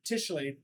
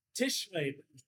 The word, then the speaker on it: Tischreden: So how do you pronounce the the German for talk? Is Tischreden. Repeat that again. Tischreden. There needs to be a Tischreden from a moonshine distillery.